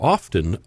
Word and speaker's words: often: often accompanied by heavy snow and strong winds. – I really dislike people who pronounce the T in the word often.